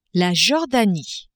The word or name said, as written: Jordanie